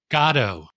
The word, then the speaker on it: Godot: Speaking about those collaborations, you did a production of Waiting for Godot. Am I saying that right? – Gatto.